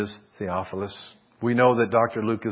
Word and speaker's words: Theophilus: With the most difficult name to pronounce, he's the most excellent, Theophilus.